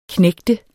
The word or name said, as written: knægt